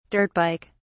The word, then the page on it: dirt bike: British English